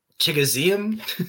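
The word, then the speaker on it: Chigoziem: His name is Chigozima. It's spelled Chigozium. There's too much syllables. – Chigozium.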